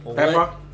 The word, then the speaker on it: Peppa: Pepe the Pig theme park has just announced, and it's going to be at Peppa.